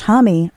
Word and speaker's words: Homme: Josh Homme, rhymes with mommy, is back with a new one featuring Nikki Lane.